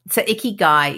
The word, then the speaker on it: ikigai: So ikigai is a Japanese phrase, and the way I remember it is it rhymes with sticky eye.